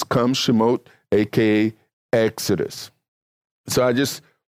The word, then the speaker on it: Shemot: And the name for the book of Exodus come from the Hebrew Shemot or Shemos, which is how it's pronounced in some translations, which in the English is translated Exodus.